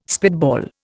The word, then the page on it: spitball: Indian